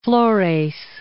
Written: Flores

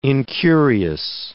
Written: incurious

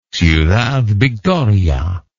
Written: ciudad victoria

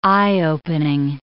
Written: eye-opening

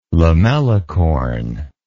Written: lamellicorn

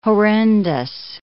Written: horrendous

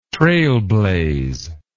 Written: trailblaze

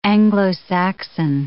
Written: Anglo-Saxon

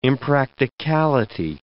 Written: impracticality

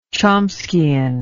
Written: chomskyan

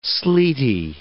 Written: sleety

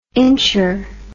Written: incher

